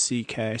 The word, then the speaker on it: C.K.: because his name is like a Hungarian name with a bunch of vowels together, but it's pronounced C.K., so he just goes with the two initials.